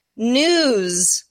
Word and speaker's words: Knewz: It's calling News, and that's spelled K-N-E-W-Z.